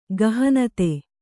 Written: ಗಹನತೆ

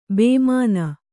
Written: ಭಾಮಿನಿ

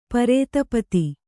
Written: ಪ್ರತಿಪತ್ತು

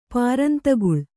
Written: ಪಾರಂತಗುಳ್

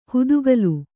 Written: ಹುತ್ತುಗಾಲ್

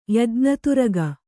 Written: ಯಜ್ಞತುರಗ

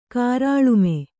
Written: ಕಾರಾಳುಮೆ

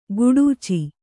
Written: ಖಡಚಿ

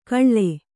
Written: ಕಳ್ಳೆ

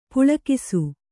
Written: ಪೊಳಕಿಸು

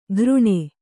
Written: ಗಾರಣೆ

